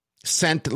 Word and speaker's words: scent: Is the S or the C silent in scent? Scent like as in smell. – Yeah. The C is silent.